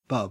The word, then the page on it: bub: Australian English